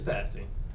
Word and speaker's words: pasty: Is that what am I pronouncing it right? Is it pasty? – It is pasty.